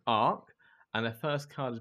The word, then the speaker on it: Arc: It's ARC. So that's ARC with a C, A-R-C, not ARC with a K, which is also an Intel tool for looking up CPU specs.